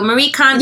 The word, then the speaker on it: Marie Kondo: Marie Kondo. Yes. Marie Kondo.